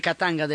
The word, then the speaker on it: Katanga: Y es cuando dicen que los belgas estaban detrás de la separación de Catania, de Catanga, de la pronuncia de Catanga, para no perder las ganancias sobre los recursos que habían en esa zona.